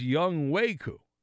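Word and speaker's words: Younghoe Koo: I mean, if you just you know, but he he says Young Waku. That's how you pronounce.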